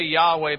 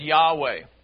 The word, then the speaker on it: Yahweh: We say Yahweh, but it's spelt YHWH. In the Hebrew language, there are no vowels. So it's generally concluded that this word is said Yahweh.